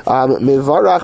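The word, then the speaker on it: mivarach (מְבָרַךְ): Mivarach b'kamatz tachas abez v'fatach tachas areish. So it's pronounced as mivarach. It's a kamatz on the base, it's mivar, and a patach on the reish is mivarach.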